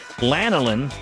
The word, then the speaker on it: lanolin: The wool covers are there to hold everything in place, and because it contains a natural wax called lanolin, I think I pronounced that right.